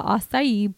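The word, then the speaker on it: açaí: Acai. – Yes, right? – Yes. So every single person who comes in has a different way to pronounce it. So the background story of the acai bowls, again, acai.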